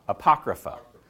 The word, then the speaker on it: Apocrypha: Apocrypha. The Apocrypha. So let's talk about the Apocrypha for just a second. Because if you go into certain bookstores, you can actually buy a copy of the Apocrypha.